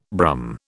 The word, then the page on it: Brum: British English